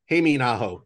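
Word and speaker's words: Hämeenaho: Lenny Haminaho? Something like that? – Heiminaho. Heiminaho? – Yeah.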